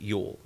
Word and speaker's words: Jule: And he was known as he was also known as Jule, or pronounced Yule. – Okay. – J-U-L-E was one of his other names.